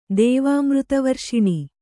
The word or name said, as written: ದೇವಾಮೃತವರ್ಷಿಣಿ